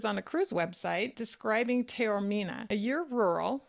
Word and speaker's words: Taormina: Taormina, but it's next to Mount Etna.